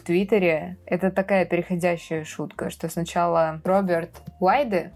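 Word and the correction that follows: Weide: Уайди, уже у него правильно произносится фамилия, э, написал у себя в Твиттере что-то типа 2020 э, год срежиссирован мной.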